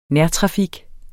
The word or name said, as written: nærtrafik